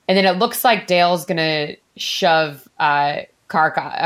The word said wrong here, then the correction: Zarkov: Uh, Karkov? Oh my gosh, Zarkov. Zarkov. – Why am I saying that wrong? I was like, that's not right.